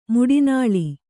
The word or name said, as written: ಮಿಟ್ಟೆನೆಲ